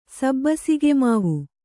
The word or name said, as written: ಸಬ್ಬಸಿಗೆಮಾವು